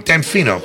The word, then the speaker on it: Damfino: And how do you pronounce it? It's so long. Damfino.